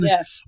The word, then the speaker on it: poutine: I don't know if it's poutine or poutine. – Poutine. I say poutine because I'm from and born and raised in Montreal and I speak French. And there's always a silent S in any word you say in French. And that's why there's a poutin, but poutin is the word.